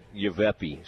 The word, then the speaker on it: Yavapai: They will play again coming up at 6 o'clock against Yavepi. I have never seen that before. – Yavapai. Spell it for me. – No, I think it's Yavapai. Yavapai.